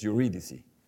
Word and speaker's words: Eurydice: Y yo entiendo que la Eurídice, ¿cómo se pronuncia en inglés? Eurídice.